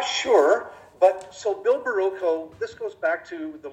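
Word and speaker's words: Bill Barilko: Is that how you say his name? He went missing. – Oh, Bill Barrillo. Yeah.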